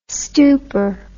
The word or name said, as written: stooper